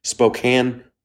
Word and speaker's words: Spokane: Spokane, Washington, December 22nd and 23rd.